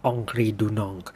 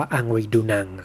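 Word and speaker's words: Henri Dunant: ที่ชื่อว่าองครีดูนองหรือคนไทยออกเสียงเพี้ยนว่าอังรีดูนัง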